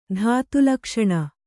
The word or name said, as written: ಧಾತುಲಕ್ಷಣ